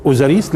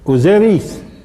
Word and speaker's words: أوزيريس: هل كانوا يقولوا أوزريس؟ لا، كانوا يقولوا لحظة أوزيريس.